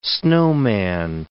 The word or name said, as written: snowman